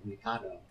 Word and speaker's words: Mikado: It's like a riff on the Mikado, or you never say it right, Mikado, where the objective is to make punishment crime.